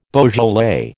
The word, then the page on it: Beaujolais: American English